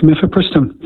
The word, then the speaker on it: mifepristone: Is it mifepristone? Did I pronounce that correctly? – That's correct, mifepristone.